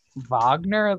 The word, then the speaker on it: Wagner: Wagner, like pronounced with a V? – Like V-O-G. N-E-R.